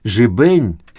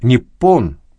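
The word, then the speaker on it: ниппон (日本): Название этого государства, которое по-китайски звучит как «жибэнь», а по-японски как «ниппон», пишется 2 иероглифами, первый из которых означает «солнце», а второй – «корень».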